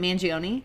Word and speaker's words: Mangione: Mangione? His Mangione? 1977, what? – Is it Mangione? – Yes.